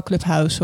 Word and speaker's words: Clubhouse: Nog steeds wel Clubhouse hoor. Want het is wel Nederlands, maar gewoon Engels uitgesproken Nederlands.